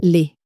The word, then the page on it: les: french